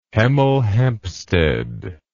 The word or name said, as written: Hemel Hempstead